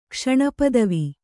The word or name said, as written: ಕ್ಷಣಪದವಿ